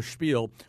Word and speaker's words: Spiel: He ends it with something called the Spiel, which he's insistent that we pronounce that way, the Spiel, which is just him kind of riffing on something.